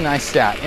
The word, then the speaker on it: Neistat: I don't remember the title of it, but it's a recent one by Casey Neistat, N-E-I-S-T-A-T, just like it sounds, if you're used to German.